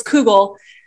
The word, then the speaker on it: kugel: Or, now I'm probably pronouncing this wrong, kugel? Kegel? Oh. Kegel? Not kegel. – Nido kegel. – Not kegel. Not kegel, everyone. Not kegel. It's kugel.